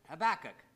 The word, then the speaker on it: Habakkuk: That's how I pronounce it Habakkuk.